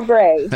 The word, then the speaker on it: Gray: Hi, I'm April Gray. – April Gray. And do you spell that G-R-A-Y? – Yes, G-R-A-Y.